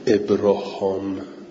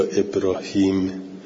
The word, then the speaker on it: ابراهیم: اولا اینکه ابراهیم حالا چه کلمه ابراهام یا ابراهیم، ما حالا بیشتر ما داریم به تلفظ عربی تکلم میکنیم، میگیم ابراهیم، و ابراهام عبریه، و یعنی اون عبریست این عربی.